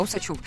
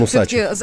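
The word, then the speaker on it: Усачёв: Как-то нативно поставил фами- фамилию ударение на букву Е, прочитав ее ну как да. Е, да, Усачев. А он Усачев. зовут его Усачев, Руслан, Руслан Усачев, Усачев да.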